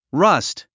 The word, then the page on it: rust: rʌ́st; ラァスト